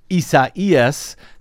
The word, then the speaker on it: Isaias: At least 4 people have now been killed as of airtime as Tropical Storm Isaias.